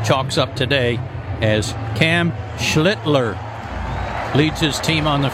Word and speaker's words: Schlittler: His last name is spelled S-C-H-L-I-T-T-L-E-R. And as Ben said yesterday, that second L is very important. to emphasize.